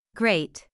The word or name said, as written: great